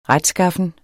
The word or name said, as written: retskaffen